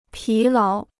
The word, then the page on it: 疲劳: pí láo